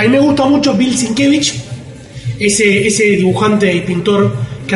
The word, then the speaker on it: Sienkiewicz: Es Sienkiewicz con Z al final y K. – Lo sabía. – Pero se pronuncia Sienkiewicz.